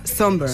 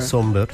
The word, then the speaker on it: Sombr: Pues mira, yo busqué cómo se pronunciaba por si acaso, pero efectivamente se pronuncia Somber. – Somber, sí. – Somber. Sí, exacto. – Y se escribe S-O-M-B-R, por si la gente lo quiere buscar.